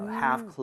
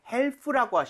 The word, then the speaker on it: half: Half closed. 오케이? 자, 여기 L 발음은 하지 않아요. 그렇기 때문에 help라고 하시면 절대 안 됩니다. – 아, 네. – 그냥 L 발음이 없다고 생각하셔야 돼요.